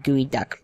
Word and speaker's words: geoduck: It's spelled differently than it looks. It looks like it's spelled like geoduck, but it's pronounced gooey duck.